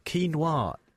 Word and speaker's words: quinoa: It's pronounced quinoa, but it's spelt with a Q, so it's Q-U-I-N-O-A. Quinoa is how it would look, right.